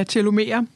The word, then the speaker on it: telomere: Men du skriver meget om, du siger, telomer. – Ja, telomere. – Telomere, satans ja. Også. Jeg sad og tænkte over, hvor jeg skulle lægge trykket. Det var 50-50, men jeg regnede forkert.